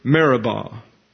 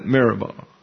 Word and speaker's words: Meribah: So with that in mind, you're familiar with the term Meribah. And I've got it correctly transliterated under my system into your notes. But you don't fight City Hall, so I'll call it Meribah.